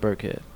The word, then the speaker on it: Birckhead: SoundCloud/ Brent Burkhead. – And how do you spell that, Burkhead? – BIRCKHEAD. – Oh, CK, okay. – It's not Brickhead.